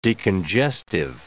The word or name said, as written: decongestive